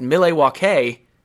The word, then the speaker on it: Milwaukee: It is actually, and for those people who are big fans of Wayne's World will know this answer, it is actually an Algonquin word pronounced Milwaukee, meaning the good land.